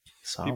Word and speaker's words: Samhain: Most scholars believe that the roots of Halloween extend back to a 2,000-year-old Celtic celebration known as Samhain, or Samhain, it's pronounced. – Samhain.